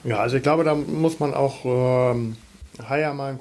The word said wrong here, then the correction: Heuer: Haier oder Heuer? – Heuer.